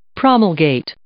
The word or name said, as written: promulgate